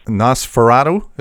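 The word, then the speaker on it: NOS4A2: And the title is actually NOS 4A2, and I titled it that to agonize the guys who do like radio shows and podcasts. – Yeah, you did well. – You have to figure out how to pronounce it on the air. – You did well. – It's a vanity license plate. If you sound it out, it spells the German word for vampire, Nosferatu.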